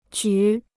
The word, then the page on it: 举: jǔ